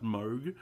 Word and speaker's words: Moog: I think it's pronounced Moog.